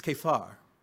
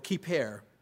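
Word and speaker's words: kipper: And again, this word atonement in Hebrew, the word atonement in Hebrew is kefar. Kefar or kiper, pronounced both ways, kefar or kiper.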